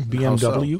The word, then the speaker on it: BMW: I didn't realize, and maybe you guys didn't either, we've been saying BMW wrong. The car brand, BMW? – So? – Yeah, apparently we said it wrong. – BMW. – BMW. Because it's German. – Oh. – They say Wiener Schnitzel when it's spelled with a W.